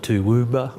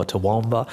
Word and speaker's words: Toowoomba: We call it Toowoomba or Toowoomba.